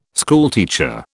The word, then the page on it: schoolteacher: British English